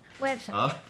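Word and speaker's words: Güepsa: ¿Cómo se dice a los que nacieron en Huespa? Perdón, Huepsa. Websa. Con diéresis.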